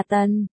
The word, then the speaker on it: Tần: À, cô hơi buồn cười, anh phát âm N và Ân không khác nhau là mấy. Phải nói là Tần, anh ta nhìn xoáy vào cô.